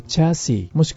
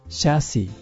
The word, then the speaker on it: chassis: チャーシーもしくはシャーシーと発音します。